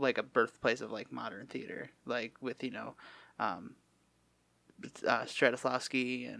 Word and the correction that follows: Stanislavski: and, did I say his name right? – Stanislavski. Yes.